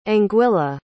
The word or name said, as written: anguilla